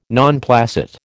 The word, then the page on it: non placet: American English